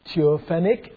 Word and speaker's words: theophanic: And so it is given the meaning, what we call the theophanic angel. Theophanic angel. In this case, that's what I pronounce in case you didn't get it. Let me spell it. T-H-E-O-P-H-A-N-I-C.